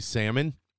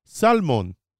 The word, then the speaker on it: salmon: So in Hebrew, you know how in the United States we say salmon? They say salmon. The L is not silent.